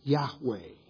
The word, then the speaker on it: Yahweh: When you pronounce those consonants, it's Yahweh.